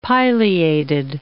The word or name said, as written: pileated